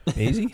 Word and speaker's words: Mazi: It's M-A-Z-I. – Mazi? – I think it's Mazi Smith.